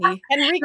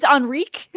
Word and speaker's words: Henrique: And then Adam, how did you say his last name? – I butchered that, didn't I? – Henrique. – Did I completely butcher? It's Henrique. – It's Adam Henrique.